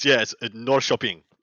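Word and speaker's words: Norrköping: Norshopping? – I looked it up and I was like, okay, there's no way I'm lining Paul up for this slaughter. So I went and looked up pronunciation. It's Norshopping.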